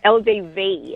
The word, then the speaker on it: LDV: You drive an LDB T60. That sounds like machinery. – LDB, is that LDB? Another Chinese car, I think? No? – You're saying it right. LDV. – Oh, LDV. LDV.